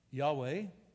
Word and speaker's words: Yahweh: We think now that it was pronounced Yahweh, the sacred name of the Hebrew God, and we are amazed at how carefully the people were kept a holy distance from it.